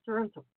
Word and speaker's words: Toronto: So first of all, how are people in Toronto doing? How are you doing? And how do you pronounce Toronto? – Oh, you did pretty good there. It is Toronto. You did fine.